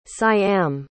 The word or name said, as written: siam